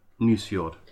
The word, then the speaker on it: Nusfjord: Nusfjord.